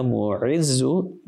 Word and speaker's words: Mu'izzu: Ya Mu'dhillu is with a dhal. And Ya Mu'izzu, there's an Ayn there.